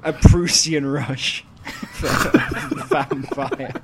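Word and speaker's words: Vampyr: Is it vampire or vampire or I think it's just vampire, honestly. – It's just vampire. – It's a silent Y.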